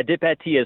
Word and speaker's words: Depete: Yeah, Depete, as French as you want to go.